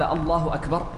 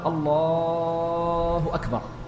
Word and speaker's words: الله أكبر: أي ألا تمد وإنما تنطق بلا مد زائد عن المد الطبيعي، فلا تقول في تكبيرة الإحرام ولا في تكبيرات الانتقال الله أكبر،